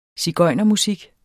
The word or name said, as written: sigøjnermusik